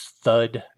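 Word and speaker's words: Pthud: And then we have an image of Red Fox and the title of the story, The Treasure of P-T-H-U-D. I'm going to pronounce that as thud and pretend that the P is silent.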